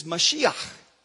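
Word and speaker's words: Mashiach (משיח): The word Messiah is Mashiach. When he is reading the scripture and then he says, today it's fulfilled. In your hearing, he is saying, I am Mashiach.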